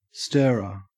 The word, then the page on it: stirrer: Australian English